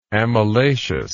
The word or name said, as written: amylaceous